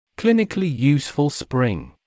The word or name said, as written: clinically useful spring